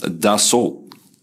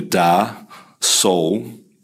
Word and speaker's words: Dassault: and she said it is pronounced Dassault. So it is basically da, sol, but the T disappears, and I'm quoting it directly, let the T disappear into the back of your mouth. So it's da sol.